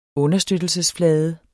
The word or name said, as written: understøttelse